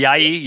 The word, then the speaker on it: Ayayi: Ayayi.